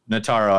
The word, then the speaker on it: Notaro: Tig Notaro. – Notaro, I said that wrong.